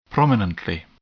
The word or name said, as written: prominently